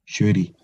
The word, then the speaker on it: Shuri: but Shuri? Is that how you pronounce it? – Shuri.